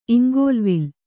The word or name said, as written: ಇಂಗೋಲ್ವಿಲ್ಲ